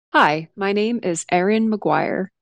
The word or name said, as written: Erin McGuire